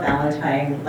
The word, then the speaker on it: Valentin: Hi, Michaela Valentine. Last name is spelled V-A-L-E-N-T-I-N and OPPD 444 South 16th Street Mall, Omaha, Nebraska.